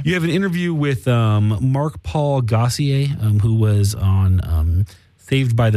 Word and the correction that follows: Gosselaar: Gosselaar. – I've been pronouncing it wrong for over a decade then.